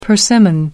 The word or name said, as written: persimmon